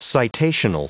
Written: citational

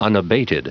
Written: unabated

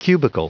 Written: cubicle

